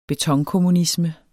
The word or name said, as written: betonkommunisme